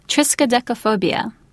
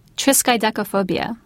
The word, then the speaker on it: Triskaidekaphobia: Triskaidekaphobia. Triskaidekaphobia.